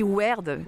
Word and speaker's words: Wyrd: Votre prochaine création, alors je ne sais pas si on dit Wyrd ou WYRD, comment on le prononce ? – On dit Word, qui est un vieux mot qu'a donné Witch Word.